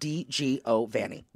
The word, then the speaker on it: DiGiovanni: Like, my name is Deborah DiGiovanni, but DiGiovanni, which people panic about all the time, they cannot pronounce it, which is funny to me because it's phonetical. – You got to say it with a hand. – First of all. But it's also exactly how it's spelled.